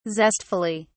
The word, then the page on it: zestfully: British English